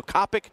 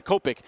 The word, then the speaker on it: Kopech: Kopech, Michael Kopech. I think that's how you pronounce his name, Kopech.